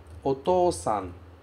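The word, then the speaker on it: Otosan (お父さん): E ela se pronuncia Otosan.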